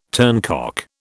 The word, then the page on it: turncock: British English